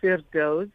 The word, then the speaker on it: Firdoze: Okay, so I'm going to start with my name, as you asked whether or not it was correctly pronounced, Firdoze Bulbulia.